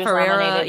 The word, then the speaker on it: Ferrera: And he's like, I'm happy for any America for Ferrara. Is that how you say it? – Yeah. Ferrara. Yeah.